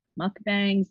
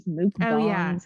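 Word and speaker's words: mukbang: And on my YouTube channel, I was doing like, oh God, everyone always says people pronounce this wrong, but mukbangs, mukbangs.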